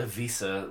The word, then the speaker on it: Ibiza: And they always, like, the summer always pains me because I've always wanted to go to Ibiza, and, or Ibiza, which is what I'm told is the correct way to say it.